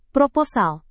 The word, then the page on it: proposal: Indonesian